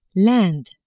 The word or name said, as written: Inde